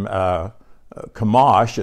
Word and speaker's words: Chemosh: And Baal, B-A-A-L, was the supreme father of the Canaanites. And then Chemosh, it's spelled C-H-E-M-O-S-H, but it's pronounced like with a K.